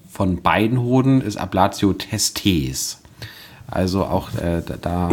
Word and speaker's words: Testes: Nicht so eine krasse Betonung auf dem zweiten E, eher so eine kurze Betonung auf dem zweiten E. – Ja. – Ablatio Testes.